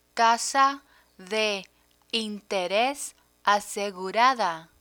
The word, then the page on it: tasa de interes asegurada: Spanish